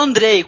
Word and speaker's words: Andrzej: Você fala Andrei quando tem J no final.